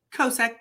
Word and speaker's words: Kosek: How do you say his last name? – Kosek. K-O-S-E-K.